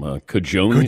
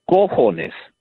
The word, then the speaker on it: cojones: But the correct way of saying it is cojones, C-O-J-O. Cajones, C-A-J-A, is drawers.